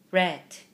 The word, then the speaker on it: read: 그래서 어떻게 읽느냐면요. read, read, red. 그 빨간색 할 때 그 red 있죠? 똑같은 네네. – 발음이에요. 그렇게 읽어요.